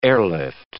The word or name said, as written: airlift